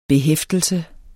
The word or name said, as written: behæftelse